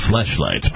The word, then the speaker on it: Fleshlight: Dude's got a flashlight. – I believe it's pronounced fleshlight.